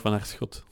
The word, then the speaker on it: Vanaerschot: Toi, tu le prononces comment? – Frank van der Schot.